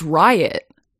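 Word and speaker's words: Ryut: No, it's spelled Ryute, but it's Riot.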